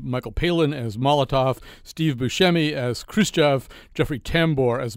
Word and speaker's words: Buscemi: It's Buscemi.